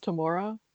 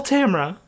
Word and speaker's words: Tamora: Her name is Tamara Pierce. I always thought it was, like, pronounced Tamora, but I read the bio and apparently it rhymes with camera, so it's Tamara.